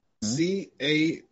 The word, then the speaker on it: Carrere: Kiari. I don't know how to pronounce her name. – Who? – Tia Carrere. Look her up. Carrere. – Oh, career. Oh, my girl.